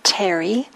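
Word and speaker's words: Therigatha: So in this collection of poems, it's called the Terigata. There's a number of different translations available. If you're interested, you could always Google it. T-H-E-R-I, Teri. We don't pronounce the H.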